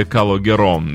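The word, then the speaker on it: Calogero: Вот интересно мне, как правильно говорить «Калогеро» или Калогером? Ну, если по-французски, то «Калогеро». Ну, так произнесем этого исполнителя.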